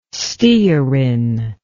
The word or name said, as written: stearin